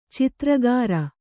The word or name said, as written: ಚಿತ್ರಗಾರ